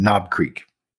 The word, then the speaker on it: Knob Creek: Like as in Knob Creek. – Oh, is that how it's pronounced? – Yes.